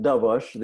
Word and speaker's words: Dobosz: So I pronounce it Dobosz. The S is like an SH, but it's not one of those easy names to pronounce.